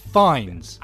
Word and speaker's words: Fiennes: How do you say it? – Fiennes.